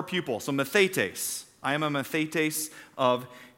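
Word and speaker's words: mathetes (μαθητής): In the New Testament, the Greek word that most of the New Testament was written in for disciple is called mathetes. Everybody say mathetes. Say it like you mean it.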